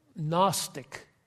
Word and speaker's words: Gnostic: We have Gnostic. Gnosticism. In English, the G is silent, but it's the Greek word for to know.